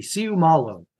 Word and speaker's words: Seumalo: I mean, every letter in Polynesian names is pronounced, so it's easy. Seumalo.